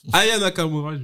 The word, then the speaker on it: Aya Nakamura: Je l'ai très mal prononcé comme Nico Sayalagas. Aya Nakamura, je voulais dire.